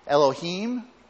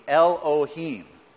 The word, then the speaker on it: Elohim: It's pronounced Elohim.